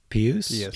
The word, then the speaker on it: Pius: Pius.